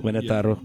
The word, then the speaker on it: Querétaro: Querétaro, right? Is that how you say it? – Querétaro.